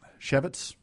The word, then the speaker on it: Chaffetz: It says here that they determined Representative Jason Chaffetz, a Republican from Utah, and Chaffetz.